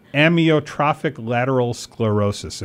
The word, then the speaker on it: amyotrophic lateral sclerosis: I'll try and pronounce it as amyotrophic lateral sclerosis, if you're wondering what ALS is.